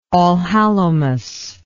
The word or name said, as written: allhallowmas